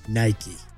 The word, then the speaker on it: Nike: 50 years after founding the company, he finally confirmed Nike. – Two syllables.